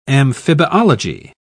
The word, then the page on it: amphibiology: British English